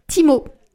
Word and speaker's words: Temu: Timo. Qui s'écrit Temu mais qu'on prononce Timo parce que c'est une application chinoise.